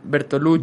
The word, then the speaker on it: Bertolucci: Y también ahorita que mencionabas de Bertucholi Bertu, ¿cómo, se pronuncia? Bertolucci. Bertolucci,